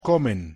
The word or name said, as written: comen